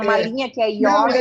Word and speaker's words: yoga: É, é que em sânscrito, a palavra yoga, o O, quando está perto do Y ali, ele é fechado, não é aberto. Então, é, é questão de pronúncia, porque o original é em sânscrito.